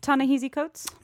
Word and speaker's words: Ta-Nehisi Coates: Ta-Nehisi Coates.